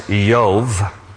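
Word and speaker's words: Yov (איוב): In Hebrew, his name is pronounced Yov.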